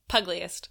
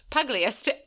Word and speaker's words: pugilist: How do you say that? – Pugliest. – Pugliest. Excellent.